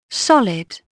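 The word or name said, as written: solid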